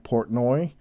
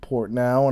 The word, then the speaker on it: Portnoy: I mispronounced a musician's name, the guest, Mike Portnoy. I was saying Mike Portnow, and I've been pronouncing it Mike Portnow for years upon years, decades even, when he was in Dream Theater.